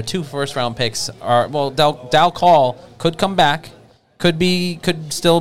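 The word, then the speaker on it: Dal Colle: Delcol, the E's, I think, was correcting our pronunciation.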